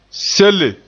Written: sele

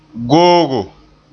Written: googo

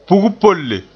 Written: pug-polle

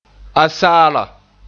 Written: asaala

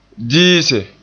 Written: diise

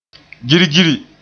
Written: girgiri

